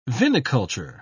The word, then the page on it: viniculture: British English